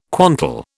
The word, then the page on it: quantal: British English